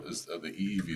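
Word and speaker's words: EEV (ʻiʻiwi): So the honey creeper, the EEV is how you say it.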